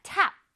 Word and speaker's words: tap: 자, tap. 발음해 볼게요. tap. tap 아니고, 여기를 올려서, 광대를 올려서 tap.